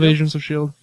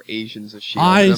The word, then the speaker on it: Agents of S.H.I.E.L.D.: Of Agents of S.H.I.E.L.D. – You pronounce it better than Ryan, because whenever Ryan says it, I always mistake for Asians of S.H.I.E.L.D.